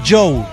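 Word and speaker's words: Jo: Me parece bien curioso Joe, de J-O. Se pronuncia Joe.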